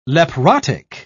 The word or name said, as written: leprotic